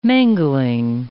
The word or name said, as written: mangling